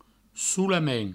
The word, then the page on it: solament: soulamen